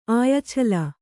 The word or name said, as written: ಆಯಛಲ